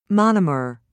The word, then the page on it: monomer: American English